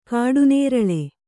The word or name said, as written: ಕಾಡುನೇರಳೆ